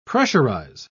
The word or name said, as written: pressurize